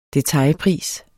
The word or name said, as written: detailpris